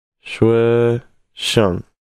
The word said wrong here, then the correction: 学校: Xue Xiao.